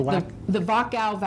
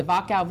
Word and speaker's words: Wachau: the Wachau Valley. It begins with a W, but pronounced with a V. The Wachau Valley.